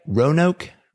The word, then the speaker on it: Roanoke: It's located in Roanoke, Virginia. Am I saying that right? Roanoke? – Yep.